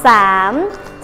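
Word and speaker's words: sảm (สาม): Số 3 đọc là sảm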